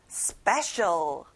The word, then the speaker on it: special: 특별한 special. 스페에 강세를 넣어보는 거예요. Special. 잘했어요.